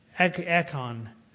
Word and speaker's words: Achan: And we know there was this one man, I can't pronounce his name, Achan, A-C-H-A-N, Achan, who did.